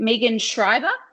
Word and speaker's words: Megan Schreiber: And is it Megan Schreiber? Is that mm-hmm. how you pronounce her name? – Yeah.